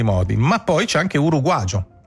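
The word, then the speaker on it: uruguayo: De Mauro, non il signore ma il dizionario, registra un uruguagio che viene dallo spagnolo americano, si scrive uruguaio con la Y, ma in Uruguay si legge uruguagio.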